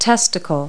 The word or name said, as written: testicle